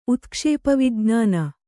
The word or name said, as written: ಉತ್ಕ್ಷೇಪವಿಜ್ಞಾನ